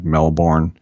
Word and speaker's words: Melbourne: So Brett Kagan, who's the author of the study and chief scientific officer at Cortical Labs in Melbourne, they always say don't pronounce the R, like Melbourne, Australia, he said, we've made huge strides with silicon computing, but they're still rigid and inflexible.